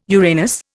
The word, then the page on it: Uranus: Indian